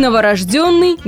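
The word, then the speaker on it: новорождённый: По аналогии и в новорожденном ударение будет на предпоследний слог. К тому же буква «ё» во всех словах без исключения ударная. И новорожденный не исключение.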